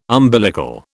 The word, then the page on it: umbilical: British English